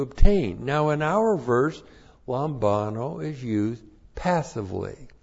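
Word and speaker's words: lambano (λαμβάνω): Looks like that in the Greek, L-A-M-B-A-N long O, lambano.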